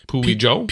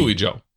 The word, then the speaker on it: Puijo: P-U-I-J-O. Puyo? Puijo? – P-P-O-P. – Puijo. It's Puijo. – Puijo. – We don't have to look it up. It's Puijo.